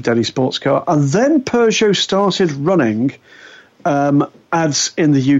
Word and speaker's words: Peugeot: and all she was doing as the sign-off was pronouncing it Peugeot.